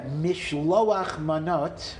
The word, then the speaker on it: Mishloach Manos: Shalach Manos is Yiddish, and Mishloach Manos is Hebrew. – Yeah, Mishloach Manot, or Manos, is how you pronounce it, is the Hebrew, and in Yiddish it got contracted to Shalach Manos.